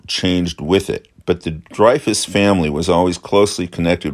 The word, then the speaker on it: Dreyfus: So in many ways, the Dreyfus Affair helped give birth to the creation of the modern Jewish state. Last but maybe not least is the fact that Dreyfus is the French pronunciation of Dreyfus, which means 3 feet.